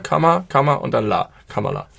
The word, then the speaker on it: Kamala: Aussprache einmal, ich habe es inzwischen gelernt, wir haben es häufig falsch gemacht, du gerade eben auch. Kamala Harris.